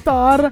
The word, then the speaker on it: Thor: È Thor.